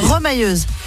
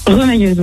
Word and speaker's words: remailleuse: Remailleuse. – Ça se prononce pas comme ça? Remailleuse? – Remailleuse, oui. – Ah, remailleuse, pardon.